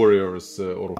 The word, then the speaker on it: Warriors Orochi: Eh, eh, eh, eh du sa det förut, Warrior Orchi eller hur man uttalar ah, det. Ja, Warriors Orochi.